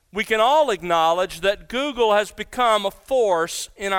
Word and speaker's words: googol: The founders of this corporation called Google took their name from a mathematical term, the word Google, pronounced the same but spelled differently. It's spelled G-O-O-G-O-L.